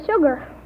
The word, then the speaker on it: sugar: Now, back to that first question who wants you to name two common words in the English language that begin with S-U, but which are pronounced as if they started with S-H. – Well, there's sugar.